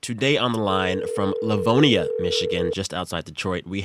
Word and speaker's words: Livonia: Did I say it right? Livonia? – Yeah. Yep. You got it right.